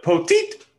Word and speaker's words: Poteet: It's poteet. Um, that's what he's always going to be to me. I don't care how many people tweet me and tell me I said it wrong.